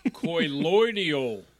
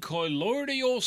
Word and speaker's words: colloidal: Okay, colloidal silver. You mispronounce it. – Shocker. – Actually, I thought I nailed that one. – No. Colloidal silver, not colloidal.